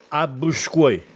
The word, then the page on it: Abbusckuè: Castellanetano